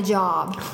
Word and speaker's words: Job: I already have a job. And the instructor said that it looks like job, but it actually is pronounced Job.